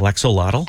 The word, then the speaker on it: axolotl: An axolotl? – Yep. – Axolotl? – Axolotl.